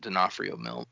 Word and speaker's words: D'Onofrio: Great Vincent D'Onofrio film, by the way.